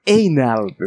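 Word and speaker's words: anal: 正しくは、エイナルですけどね。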